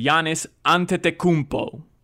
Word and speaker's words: Giannis Antetokounmpo: And you mispronounced his name last segment. It's taken me very, very, very many years to say this correctly. Giannis Antetokounmpo.